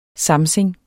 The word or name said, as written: samsing